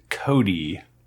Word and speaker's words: Chode: Pronounce Cody, by the way. – Cody, C-H-O-D-E, Cody.